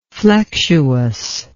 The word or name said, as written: flexuousness